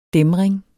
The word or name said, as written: dæmring